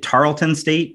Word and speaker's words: Tarleton: I saw, is it Tarleton State? Is that how you say it? – Yeah.